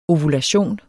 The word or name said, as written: ovulation